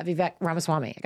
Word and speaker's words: Vivek Ramaswamy: And I know Chris Christie had a, you know, kind of a back and forth with Vivek, or mm-hmm. I'm saying that wrong. Vivek Ramaswamy. I got the last name right, yes. – Right? Vivek. Okay, yeah.